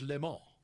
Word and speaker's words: Le Mans: How do you pronounce it? – It's Le Mans. – Le Mans. – Le Mans. – Le Mans. – Le Mans. – Okay. – The S is silent.